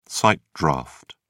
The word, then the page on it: sight draft: British English